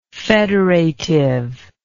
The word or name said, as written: federatively